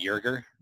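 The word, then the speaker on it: Joerger: They mentioned the Lakers assistant Jason Kidd, the Hawks interim coach and former Blazers head coach Nate McMillan, Philadelphia 76ers assistant Dave, is it Yerger? Did I say that right? I think so, yeah.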